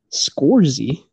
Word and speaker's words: Scorsese: Okay, well, it would help if you said the name right. Scorsese. Holy shit. – Scorsese? I was like, who the fuck is that? Scorsese. It's Italian.